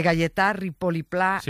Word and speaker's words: Gaietà Ripoll i Pla: a ver si lo digo bien, de Gaietà Ripoll i Pla, un mestre y pensador que tenía la seua escola a l'Horta de Rusafa. – Perfecto. – ¿Lo he dicho bien? – Sí, vale. Y se ha entendido perfectamente.